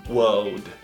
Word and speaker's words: world: E o world é muito mais fácil no inglês britânico. Você não vai pronunciar o R. Você vai falar somente world. Mas para pronunciar mundo em inglês, não é difícil como você imagina. Você só precisa pronunciar o R no passado. Were e depois velho. Lembre-se sempre dessa regra. Old. Were old. Juntos dois, fala um pouquinho mais rápido. World. World. World.